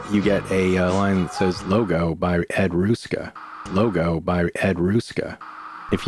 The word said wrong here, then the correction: Ruscha: you know anything about pop art, you're familiar with that name, E-D-R-U-S-C-H-A. If I'm not pronouncing that correctly, I'm sorry, Ruska. But I'm pretty sure it's Ruska. – I mean to say, Ed Ruscha.